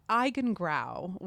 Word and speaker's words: Eigengrau: Yeah, it's Eigengrau, which is a German word for the color that you see when your eyes are open, but there's no light.